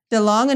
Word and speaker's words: DeLonge: DeLonge announces creation of fringe science research team.